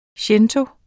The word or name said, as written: shinto